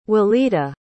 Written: Walidah

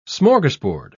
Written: smorgasbord

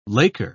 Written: Laker

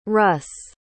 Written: russ

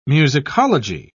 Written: musicology

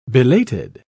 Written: belated

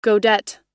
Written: godet

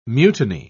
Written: mutiny

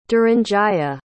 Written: duranjaya

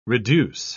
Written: reduce

